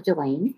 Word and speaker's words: Gelaine: So my name is Gelaine Santiago, which is like Elaine with a G. That's pronounced like a J. So Gelaine.